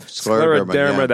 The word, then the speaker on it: scleroderma: and has a great scleroderma. I can never pronounce it properly, Bob. – You did pretty good. – Scleroderma.